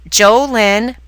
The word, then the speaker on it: JoLynn: Go to YouTube and look up my name, JoLynn Braley. It's spelled J-O capital L-Y-N-N, Braley, B-R-A-L-E-Y.